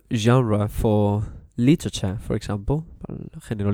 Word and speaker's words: genre: You know, now I would like to speak about something which is, you know, very, very easy, and it's the term genre. It's very easy, but sometimes hard to pronounce for, you know, some students. So it's G-E-N-R-E-S, genre. It comes from French, and it means género, género musical.